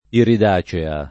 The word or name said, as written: iridacea